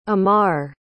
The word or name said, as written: ommar